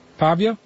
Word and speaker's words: Pavia: He studied at the University of Venice, the University of Pavia. Is that how we pronounce it? Pavia? – Pavia.